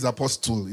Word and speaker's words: apostle: For those who don't know, the tea is silent, okay? But it's fine if you say he's apostle.